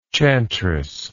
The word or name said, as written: chantress